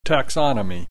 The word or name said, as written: taxonomy